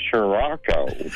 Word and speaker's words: Scirocco: And I said a Scirocco, and I told David L., and he said, I think that said Scirocco. – Yeah, that's how it should be pronounced.